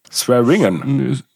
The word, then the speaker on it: Swearingen: wie spricht man das? Swearinger? Swearinger? – Mhm. – Swearingen. Swearingen. Swearingen.